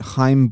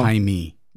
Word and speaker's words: Chaim: but Chaim Bloom, the wonderkin down in Tampa, is now Jaime.